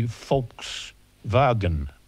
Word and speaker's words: Volkswagen: It could be Volkswagen. – Mm, yeah, if you pronounce the W as the Germans do, as a V.